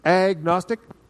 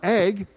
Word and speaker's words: agnostic: Anybody ever heard of agnostic? Okay, we've gone through this before. I just want to touch base on it. What is an agnostic? It's not ag, it's a-gnostic. G-N, the G is silent.